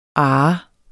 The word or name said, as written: arh